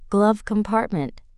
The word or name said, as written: glove compartment